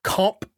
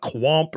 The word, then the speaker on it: Qomp: So the game is called Comp 2, but it's spelled Q-O-M-P, comp. There's no U in it. It's not quomp or anything.